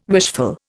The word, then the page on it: wishful: Indian